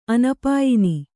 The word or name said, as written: ಅನಪಾಯಿನಿ